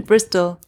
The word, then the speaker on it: Bristol: Ja, und in Oxford und Brüssel. – Brüssel? In Bristol.